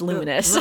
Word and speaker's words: Luminous: Lumonious? – That was my highlight, because that was something new for us. – I keep saying Lumonious. It's Luminous.